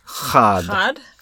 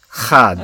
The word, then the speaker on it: Chod: that lore or, legend has it that Cockrum had meant for the CH to be like the Hebrew CH. Huh. So that it would be pronounced Chod. – Chod.